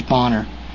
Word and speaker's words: Boehner: or Bonner. I always say Boner. It's probably, he probably prefers Bonner, but it's spelled B-O-E-H-N-E-R. That's Boner.